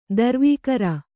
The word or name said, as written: ದುರ್ವಿಕಾರ